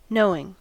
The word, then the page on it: knowing: American English